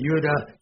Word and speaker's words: Judah: every time there's a J, you would say Judah, Joash, but, we'll pronounce it with a J because you'll think I'm crazy if I say pronounce it with a Y, okay?